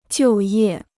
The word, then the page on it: 就业: jiù yè